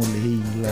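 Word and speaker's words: Layla: Se escreve Laila, mas ele interpreta como Leila, né?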